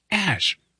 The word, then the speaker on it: Azsh: The name is spelled A-Z-S-H, but it's pronounced Ash.